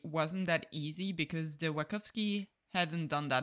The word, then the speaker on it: Wachowski: How do you say it? – I would say Wachowski. – Wachowski, okay, same.